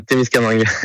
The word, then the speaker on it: Témiscamingue: Alors, est-ce qu'on prononce bien Témiscamingue, du coup? – Ouais, c'est bien ça, Témiscamingue. Exactement.